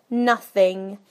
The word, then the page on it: nothing: British English